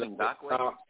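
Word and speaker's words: Ngakoue: but Ngoku. He's the one to the logo, to the Chiefs logo, for their pregame meeting. – I think it's pronounced Ngakwe.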